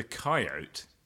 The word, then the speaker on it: coyote: Yes, and interestingly, he refers to the coyote. Coyote, so that it rhymes with Capote.